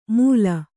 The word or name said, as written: ಮೂಲ